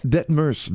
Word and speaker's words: Dettmers: Madame Detmer, Sarah, est-ce que c'est comme ça que je prononce votre nom de famille? D-E-T-T-M-E-R-S, Detmer? – On l'appelle bien, c'est Detmers. – Detmers, bon, merci.